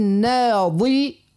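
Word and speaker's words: ناضرة: لا، لولانية بالضاد والثانية بالضاء. – إيه وكيفاش ننطقوها؟ – يعني نقول وجوه يومئذ ناظرة ناظرة ضاد اللي هي ناظرة إلى ربها ناظرة ضاء ضاء والأخرى ضاد.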